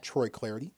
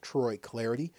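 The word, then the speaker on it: Troy Clardy: Hit me up on Twitter, at Troy Clarity. Last name is spelled C-L-A-R-D-Y, at Troy Clarity.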